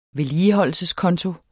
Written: vedligeholdelseskonto

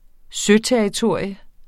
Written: søterritorium

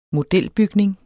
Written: modelbygning

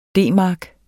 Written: D-mark

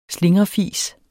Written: slingrefis

